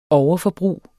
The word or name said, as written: overforbrug